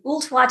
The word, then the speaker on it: Ooltewah: Applebrook is located in Ooltewah, Tennessee, which is just north of Chattanooga, which is easier to say.